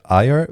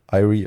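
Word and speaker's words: Eyre: Regisseur war Richard Eyer, würde ich es aussprechen. Eyrie ist, glaube ich, falsch.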